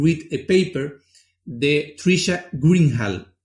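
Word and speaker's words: Greenhalgh: Trisha Greenhall es como se pronuncia y lo pondremos ahí para se llama How to Read a Paper.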